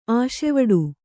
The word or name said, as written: ಆಸೆವಡು